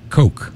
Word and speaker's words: Koch: The featured track, K-O-C-H is how you say it. That's all I got to say about that. Coke.